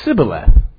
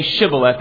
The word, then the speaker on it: Shibboleth: And he said, "Sibboleth." For he could not frame to pronounce it right. Because of his accent, he couldn't say Shibboleth. It came out Sibboleth.